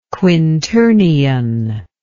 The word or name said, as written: quinternion